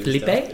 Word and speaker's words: Filippo: We said his name correctly. – Filippo? – Yeah.